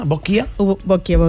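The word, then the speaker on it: boccia: que és la bòquia. No sé si ho pronuncio bé. Molt bon dia. – Molt bon dia. – Ho dic bé, això? És bòquia o bòchia? – Bòquia? Bòquia, bòquia.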